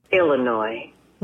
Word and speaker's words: Illinois: Illinois. – S is silent.